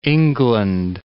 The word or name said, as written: England